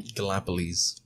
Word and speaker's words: Gallipolis: I probably said that wrong. I'm sorry. – Gallipolis. – There you go.